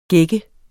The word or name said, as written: gække